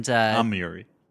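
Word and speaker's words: Amurri: Eva Muri. – Eva Muri. And Amiri. – Yeah, you're saying it right.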